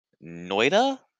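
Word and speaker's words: Noita: Noida, I believe it's pronounced.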